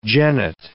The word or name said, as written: genet